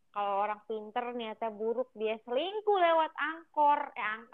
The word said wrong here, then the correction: anchor: apa sih bacanya Anchor, anchor.